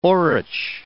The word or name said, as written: orach